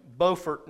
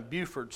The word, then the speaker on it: Beaufort: There is a Beaufort, North Carolina, and a Buford, South Carolina. You spell it the same way.